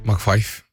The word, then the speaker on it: MacPhie: Så nå McPhee, hvordan vil du uttale det der? M-A-C-P-H-I-E. – McPhee. – McPhee,